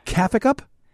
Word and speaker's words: Kaffekup: How would you pronounce this name? Because he comments twice on, and they're really smart, but it's spelled K-A-F-F-E-K-U-P. – Get puffy? – You just added letters. Cafe cup? Cafe cup? – Yeah. – Cafe cup. Let's say cafe cup.